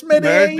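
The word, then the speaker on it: Medellín: Yeah, that's why it's Medellin. Medellin and Santa Fe. You have to pronounce it properly, and you know, apparently, it sounds strange when you're speaking American.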